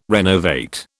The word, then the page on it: renovate: British English